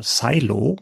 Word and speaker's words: Silo: Und dann haben wir noch Silo oder Silo, äh, englisch ausgesprochen, eine neue Mystery-Serie, die in einem riesigen unterirdischen Bunker spielt.